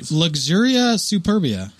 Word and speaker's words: Luxuria Superbia: It's Luxuria Superbia.